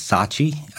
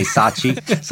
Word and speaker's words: Sachi: My family, my wife's name is Sachi, Sachi, S-A-C-H-I. The easy way to remember is, you know, there's a brand name called Versace. So take out the V-E-R and just say Sachi. So that's the proper pronunciation.